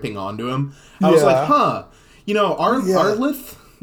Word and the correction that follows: Amleth: Amleth. I think it's, like, it's Amleth. Just Hamlet, but they switched the letters.